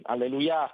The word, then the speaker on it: alleluia: che correttamente avrebbe l'accento alla fine, per cui alleluia,